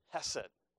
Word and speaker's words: hesed: It's kind of, you can kind of pronounce it like this, hesed.